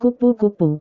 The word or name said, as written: kupu-kupu